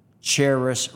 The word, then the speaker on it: Charis: With me today is Charis, rhymes with Paris, Santilli.